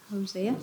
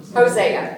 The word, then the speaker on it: Hosea: So, we have got, now I don't know, how do you all pronounce it? – Hosea. – Hosea, right. Because I always get somebody to go, "No, it's whatever it is." So we'll go for Hosea.